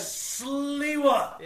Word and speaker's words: Sliwa: Am I getting that right? Sliwa? – How do you spell it? – Sliwa. It's the W-A there at the end. – Sliwa. – Yeah.